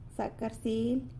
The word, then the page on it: Zacarsily: sanlucasquiavini